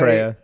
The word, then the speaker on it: Eritrea: Gannibal was born around 1698 in, I'm going to mispronounce this, Eritrea? Something like that? Anyway, Eritrea, yeah. – Eritrea, okay.